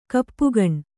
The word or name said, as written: ಕಪ್ಪುಗಣ್